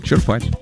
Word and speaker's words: черпать: Правильное ударение на первый слог. Этот вариант зафиксирован в большинстве нормативных словарей. Черпать на «е». И ударение на «е» сохраняется и в других глагольных формах. Черпал, черпая, исчерпав, исчерпавший.